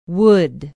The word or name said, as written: wood